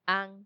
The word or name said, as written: an